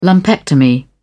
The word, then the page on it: lumpectomy: British English